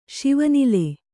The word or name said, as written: ಶಿವನಿಲೆ